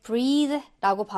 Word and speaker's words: breathe: 요길로 넘어오면 breathe. 끝에가 이로 끝나면 이게 breathe라고 발음이 되면서 이게 숨쉬다 호흡하다인데요.